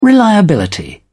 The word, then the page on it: reliability: British English